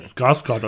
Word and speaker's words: Skarsgård: Skarsgård, also passt schon.